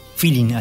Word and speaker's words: feeling: Bola, se dice Martirio, era vanguardia, grandísimo intérprete del llamado bolero feeling, feeling, así como se escribe, así le decían en Cuba, estuve leyendo por ahí, en Cuba le llaman a este bolero feeling, ¿no?